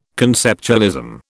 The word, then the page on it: conceptualism: British English